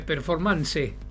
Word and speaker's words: performance: Se escribe performance. Y se dice performance. ¿Sí? Bien, acento grave. Grave. No es esdrújula.